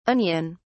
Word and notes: onion(s): British English

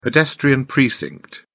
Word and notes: pedestrian precinct: British English

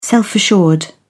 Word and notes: self-assured: British English

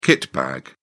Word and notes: kitbag: British English

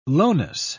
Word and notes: lowness: British English

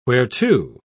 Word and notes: whereto: British English

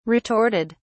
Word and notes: retorted: British English

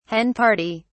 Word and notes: hen party: British English

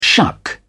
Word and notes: shuck: British English

